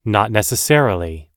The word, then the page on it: not necessarily: American English